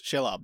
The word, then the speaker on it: Shelob: Shelob? – Yes, Shelob.